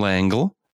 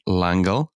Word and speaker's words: L'Engle: Madeleine L'Engle, uh, and that was not me mispronouncing that's French langle, um, describes a tesseract as a shortcut between three dimensional places in her 1960 book.